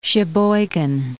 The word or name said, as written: Sheboygan